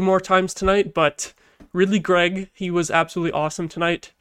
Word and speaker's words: Greig: But yeah, Ridly Greig's debut, or Greig, I said it right away. Oh my god. Ridly Greig's debut. I don't know why, but I always mispronounce this dude's name. I always read it in my head as Greig, and then that's how I've been saying it this whole time, and then it's Greig, and so I need to get that straight.